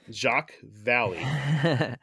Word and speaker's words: Jacques Vallée: Jacques Vallée. – You pronounced it right. – Jacques Vallée.